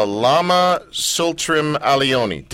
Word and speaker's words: Tsultrim Allione: Well, well speaking of names that I've been struggling to pronounce, uh, Lama Tsultrim Allione.